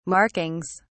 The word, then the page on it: markings: British English